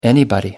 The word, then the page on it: anybody: American English